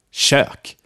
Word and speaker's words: kök: And lastly, we have kök, which means kitchen and is spelled with a single K. Kök.